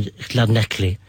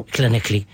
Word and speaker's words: Llanelli: We did a show, must have been the 60s, down in Llanelli. That's a proper way to say it, Mark. – Is it? – Don't look so surprised. – No. – Llanelli.